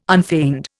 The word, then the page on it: unfeigned: Indian